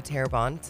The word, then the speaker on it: Terrebonne: And it's right outside of Terrebonne.